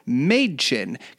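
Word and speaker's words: Mädchen: And she said her name is pronounced Madchen.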